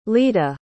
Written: leda

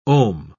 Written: ohm

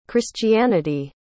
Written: christianity

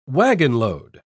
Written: wagonload

